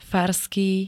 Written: farský

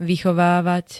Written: vychovávať

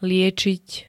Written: liečiť

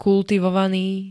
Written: kultivovaný

